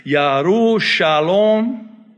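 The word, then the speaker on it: Yerushalayim: Oh, by the way, parenthetically, let me say, Yerushalom, Jerusalem, Arabic, Yerushalom, they don't pronounce the J in Hebrew.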